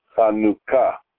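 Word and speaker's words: Chanukah (חנוכה): Hanukkah is pronounced Chanukah in modern Hebrew.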